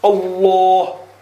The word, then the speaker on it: Allah: Allah, Allah. – So the tongue, the back is rising towards the palate and giving that fullness in the sound while it's being pronounced, while it's coming out.